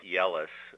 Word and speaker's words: Jelles: It's spelled J-E-L-L-E-S. And I'm not sure if the correct pronunciation is, but we always pronounce it as Jealous. – I know. I was going to wonder if he went to a one-room schoolhouse, whether he was taunted for that unusual name. But it's actually Dutch origin, so they might have pronounced it Yellis, or we've also seen it as Giles in the English version of it.